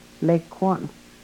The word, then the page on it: lekwon: Yurok